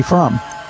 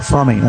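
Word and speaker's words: Fromme: And the person who eventually was connected with this, with Manson in prison and Scientology, then later became a friend of Squeaky Fromm, or Frommy, as her name's actually pronounced,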